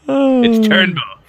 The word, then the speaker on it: Turnbull: He was apparently calling him Prime Minister Trumbull a bunch of times. – Mr. Trumbull. It's Turnbull.